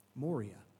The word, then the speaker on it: moria: Moria is really kind of the way it's pronounced.